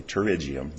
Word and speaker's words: pterygium: One was a pterygium, that's how it's pronounced, and the other are cataracts.